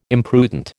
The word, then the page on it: imprudent: American English